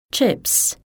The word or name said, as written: chips